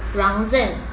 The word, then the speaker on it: Rangzen: 要用那个藏文念作 Rangzen， Rangzen 是独立，自由就是 Rangzen 这样子。